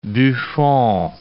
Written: Buffon

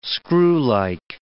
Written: screwlike

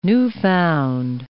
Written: newfound

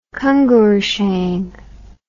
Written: Kongur Shan

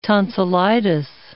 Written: tonsillitis

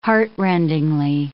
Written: heartrendingly

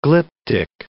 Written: glyptic

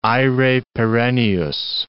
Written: aere perennius

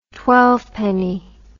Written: twelvepenny

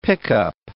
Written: pickup

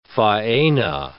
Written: faena

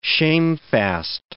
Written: shamefast

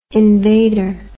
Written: invader

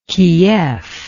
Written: keef

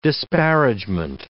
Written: disparagement

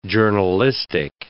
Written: journalistic

